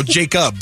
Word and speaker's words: Jakub: Did you say Jason? Sounds no, Jacob. – Oh, Jacob. – But it's spelled J-A-K-U-B.